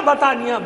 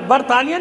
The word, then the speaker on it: बतानिया: तो एक शहर था बतानिया, बरतानिया नहीं, रे हटा दें तो वही हिज्जे बतानिया, तो एक के साथ बतानिया नाम का ये इलाका था उनका, यहाँ वो कौम आबाद थी।